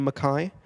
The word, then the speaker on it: Mackay: I'm in Mackay right now, if I'm pronouncing that right. Have you know Mackay? – Mackay, yep.